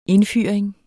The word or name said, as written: indfyring